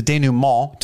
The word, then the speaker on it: denouement: And because there's no Steve, I believe that is the denouement, to throw back my French word pronounced perfectly from earlier.